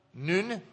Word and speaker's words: nun: Remember Joshua, the son of Nun? That's how you pronounce it, is Nun.